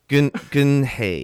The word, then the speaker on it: Geun-hye: Geun-hye.